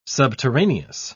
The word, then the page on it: subterraneous: British English